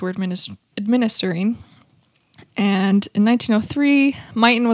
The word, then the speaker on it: Myton: We should talk about the Buffalo Soldiers sometime because they lived in Fort Duchesne, which is by Myton. Oh, I just said it correctly. – Myton.